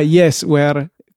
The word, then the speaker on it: Yesware: Ah, Yesware come software, però con Yesware.